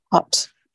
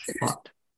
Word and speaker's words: Haupt: Her last name is spelled H-A-U-P-T. – Hopt. – Yep. Who's that? – Hopt.